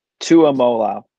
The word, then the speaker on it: Tuimoloau: What is it? Tumulale? – Tuimoloau.